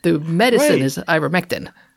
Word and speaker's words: ivermectin: But there's an A on the end. – No. – Ivermectina? Yeah, there's an A on the end. – No. – A silent A. – Invisible A. – It's right on the box. – Oh, well, that's the brand name. That's not the drug.